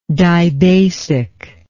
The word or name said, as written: dibasicity